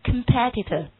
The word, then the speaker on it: competitor: 好，那今天我们来再学一个词 competitor，它的读音变了，前面的几个读音都变了，上面读 competition，对不对？下面一个读 competitor，以 OR 结尾， OR 结尾一般我们都说是 ER 结尾， OR 结尾习惯上是指什么的多？